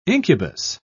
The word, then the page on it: incubus: British English